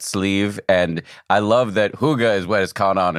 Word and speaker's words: hygge: And hygge, am I pronouncing that right? – Well, that's Danish, so that's a little out of my expertise since I'm Swedish, okay. But I would pronounce it hygge.